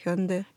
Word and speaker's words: Hyundai: А, кстати, как правильно произносится на корейском? Hyundai, Hyundai? – Hyundai. – Hyundai. – Hyundai. – Да. Hyundai. – Hyundai. Да. Второй слог.